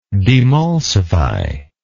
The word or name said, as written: demulsify